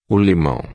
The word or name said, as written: limao